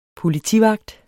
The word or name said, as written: politivagt